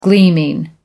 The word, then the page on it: gleaming: American English